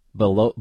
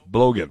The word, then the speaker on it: Balogun: So how do you say this guy's name? – I'd have to go back and look at it. – Come on now. Falarin. Balogun? – Balogun. Balogun. – Balogun. Yeah. Okay.